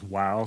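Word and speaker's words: Vav: Yeah, the V is sometimes pronounced Wow, so sometimes they'll say Yod, He, Wow, He, something like that.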